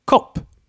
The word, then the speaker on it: kopp: And then we have the short o, used in kopp, which means cup. Kopp.